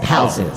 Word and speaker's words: Palzu: I said Palzu. Come on, I'm in your head, guy. It's like perfect pronunciation.